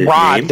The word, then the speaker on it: Raad: But he's going to be working with Keith Rad, R-A-A-D is his Rod. – Name. – Rod. – Rod. Is that how it's pronounced? – Yes, Rod.